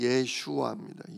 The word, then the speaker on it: 예슈아: 또 예수님의 원래 이스라엘 발음이 예슈아입니다.